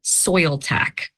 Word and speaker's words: Soiltac: Yeah, S O I L T A C, soil tech.